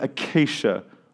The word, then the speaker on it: acacia: They're both made of acacia wood. Did I say that right, Paul? Thank you. They're both made of acacia wood.